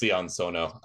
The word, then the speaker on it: Sion Sono: So first up, we have the director, Sion Sono. I'm not quite sure if I'm pronouncing that right. – Yes, Sion Sono.